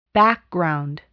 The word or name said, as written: background